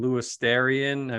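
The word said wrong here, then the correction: Luostarinen: Oh, Lustarainen on the top line?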